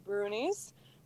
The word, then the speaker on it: brownies: I'm going to talk to you guys about Brunies, which are a Scottish, a creature from Scottish lore. The word is spelled like brownies, like the dessert, but it's pronounced brunies.